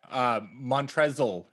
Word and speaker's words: Montrezl: Montrezl Harrell. Don't forget that silent L in there.